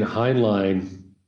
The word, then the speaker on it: Heinlein: Now, already you're saying Heinlein. In my mind, I was pronouncing it Heinlein. – Oh, which is the right pronunciation.